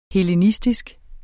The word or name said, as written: hellenistisk